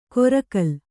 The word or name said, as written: ಕೊರಕಲ್